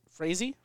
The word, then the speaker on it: Frazee: But so what ends up happening is a guy named Harry Fra- Frazee. Frazee. I don't say his name. His last name's like a freeze, like a Harry.